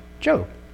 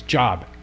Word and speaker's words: Job: It's always fun with catechism because they've never seen the word, never spoken, so first time they pronounce it, it's job. And you chuckle and say, no, it's Job. It's Job.